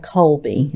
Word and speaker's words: Kolbe: I took a test that's called Colby. It's spelled K-O-L-B-E.